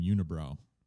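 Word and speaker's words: Unibroue: And I'm just going to pronounce them unibrow. – Yeah, that's correct. – All right, good. – That's how you say it in French.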